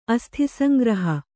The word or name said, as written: ಅಸ್ಥಿಸಂಗ್ರಹ